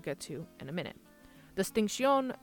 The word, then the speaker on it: distinción: For the most part, what that means is that they practice the linguistic terms of ceseo, spelled C-E-C-E-O, and distinción, spelled D-I-S-T-I-N-C-I-O-N. The O has an accent on it, which makes it distinción. The emphasis is on the O at the end.